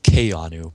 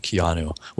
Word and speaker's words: Keanu: But his name, Keanu, is actually supposed to be pronounced Keanu, but everyone says it Keanu,